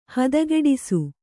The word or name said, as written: ಹದಗೆಡಿಸು